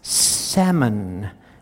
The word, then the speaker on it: salmon: The word is salmon. We don't pronounce the L.